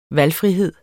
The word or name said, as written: valgfrihed